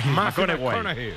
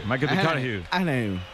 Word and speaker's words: McConaughey: Matthew McConaughey. – Sí. – Hay que ir corrigiendo la pronunciación. – McConaughey. McConaughey.